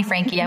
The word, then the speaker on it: Franke: And as Jenna mentioned, it's Frank with an E. I know it's spelled funny. You can call me Frankie. I won't be offended. A lot of people think that's how it's pronounced.